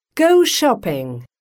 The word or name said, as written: go shopping